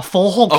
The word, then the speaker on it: folk: You pronounce the L in folk.